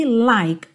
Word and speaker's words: like: De novo aqui, like, o E não é pronunciado, o K já junta no I.